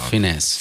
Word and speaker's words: Fiennes: Fiennes. No, say it correctly. – Correct. Fiennes.